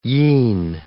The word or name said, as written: yean